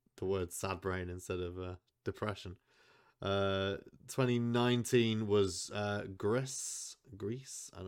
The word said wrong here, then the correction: Gris: Yeah, it's Gree. It's French, and you know that because you don't pronounce half of the word. – I mean, there's only one letter in that word you don't pronounce, and it's the S.